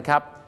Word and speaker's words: coup: สียงว่า democracy crazy แปลว่าบ้าอย่างนี้แปลว่าประชาธิปไตยบ้าหรือเปล่าครับผมแหมไม่ใช่นะครับประชาธิปไตยต้องออกเสียงว่า democracy นั่นเองครับเมาะฮะเหมือนป่าละเมาะอะไรเงี้ยนะฮะ democracy ครับผมแต่ประเทศไทยของเรานั้นก็จะมีปัญหาอยู่อย่างนึงครับที่ทำให้ประชาธิปไตยกลายเป็น democracy เหมือนกันนะฮะเพราะเรามักจะมีการทำอะไรกันครับก็คือรัฐประหารนั่นเองรัฐประหารนะครับจะสะกดเป็น COUP ไม่ยากครับคลองเล็กกับคลองคืออะไรครับคู COUP ออกเสียงว่าคูนะครับคูมาจากภาษาฝรั่งเศสคือคูเดต้าดังนั้นครับ